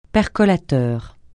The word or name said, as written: percolateur